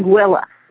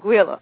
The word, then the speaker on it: Anguilla: Well, it rhymes with vanilla. – Say it again. – Anguilla. – Like vanilla. – Anguilla. – Okay. Anguilla.